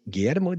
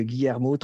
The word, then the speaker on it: Guillermo: Mais euh, donc, du coup, je l'avais signé Guillermo de Bisotto euh, parce que ben, séduction, forcément, parce que Guillermo, trois syllabes, Bisotto, trois syllabes, le 10, c'est le miroir du coaching.